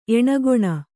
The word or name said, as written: ಏಣಗೋಣ